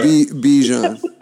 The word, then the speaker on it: Bichon: Yeah, it's like the mustard Dijon, but with a B. Yeah. Bichon. Yeah, like Bichon mustard.